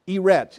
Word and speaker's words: eretz: Eretz, E-R-E-T-Z is the way you would spell it in English, eretz.